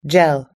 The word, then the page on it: gel: American English